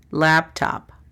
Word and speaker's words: laptop: D-E-S-K-T-A-P, desktop. Laptop. – Computadora portátil. – La pronunciación fonética es L-A-P-T-A-P, laptop. La siguiente palabra es mouse. – Ratón de escritorio. – La pronunciación fonética es M-A-U-S.